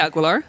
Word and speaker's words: Aguilar: Oh no, that's probably not how you say it. Help me out. How am I supposed to say it? – I say Aguilar. – Oh, yeah, Aguilar. – Yes.